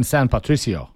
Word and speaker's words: San Patricio: A listener also pointed out the name of the Irish battalion that fought in the 1848 U.S.-Mexico War was the San Patrico, I guess I mispronounced it, battalion, which in Spanish would be St. Patrick Battalion. – Oh, Battalion San Patricio.